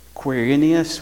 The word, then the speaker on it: Quirinius: Quirinius.